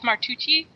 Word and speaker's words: Martucci: Like, I want to maintain the pronunciation of my last name as Martucci, no matter what my sister does.